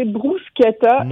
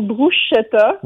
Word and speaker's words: bruschetta: J'en profite pour dire qu'il faut prononcer bruschetta et non bruschetta.